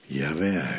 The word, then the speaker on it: Yahweh: Als je inademt is het. En als je uitademt. Dus met je in- en uitademen spreek je die naam Yahweh uit.